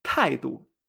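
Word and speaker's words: taidu (态度): Actitud es taidu.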